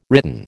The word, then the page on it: written: American English